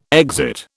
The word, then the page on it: exit: British English